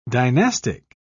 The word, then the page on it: dynastic: British English